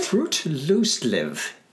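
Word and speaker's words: friluftsliv: Frutlustliv. – Okay. – That's how you say it, pronounce it in Norwegian.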